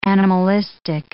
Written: animalistic

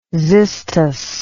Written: xystus

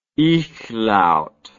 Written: ichlaut

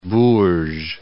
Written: Bourges

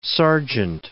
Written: Sargent

